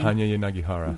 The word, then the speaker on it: Hanya Yanagihara: Hanya Yanagihara.